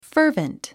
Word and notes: fervent: fə́ːrvənt; ファーバント

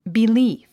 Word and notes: belief: bilíːf; ビリーフ

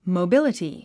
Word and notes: mobility: moubíləti; モォビリティ

